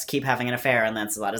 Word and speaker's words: Lancelot: The other thing is that they all pronounce the names the French way. So Lancelot is Lancelot.